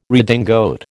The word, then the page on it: redingote: American English